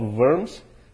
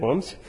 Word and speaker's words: Worms: And this was at I guess German pronunciation, when you have a W, is really a V sometimes. So this would be Diet of Worms, not Diet of Worms.